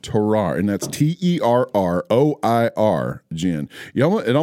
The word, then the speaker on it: terroir: You have to think French when you're trying to pronounce that. – Yeah. A terroir.